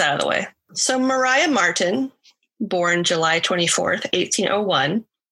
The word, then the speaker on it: Maria: Her name is spelled Maria, but they pronounce it Mariah.